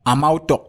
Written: AMAUTOK

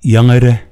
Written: Yengede

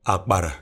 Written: Akpara

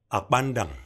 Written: akpandan

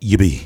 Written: Yibi

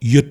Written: Yut

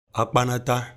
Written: akpanata

